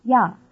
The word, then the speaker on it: 呀: Notice that after the word 车, the marker a is pronounced ya.